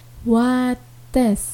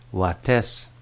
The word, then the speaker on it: Wates: W-A-T-E-S. – Wates. Ucapannya bagaimana? – Wates.